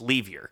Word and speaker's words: Lever: So, but yeah, Lafayette Livier or Fat Livier but it's spelled L-E-V-E-R. So it's not Lever. It's Livier. So.